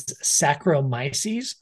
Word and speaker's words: Saccharomyces: The second category is Saccharomyces. I can actually pronounce that one. So that one's easy. So whenever you see sacro, and S-A-C-C might be an H-R-Y, something like that.